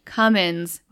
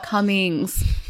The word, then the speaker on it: Cummins: It's Dan Cummins, not Cummings. And I'm like, so sorry. I'm so sorry. – That's funny. – But I also don't listen to Dan Cummins as much as Brad does.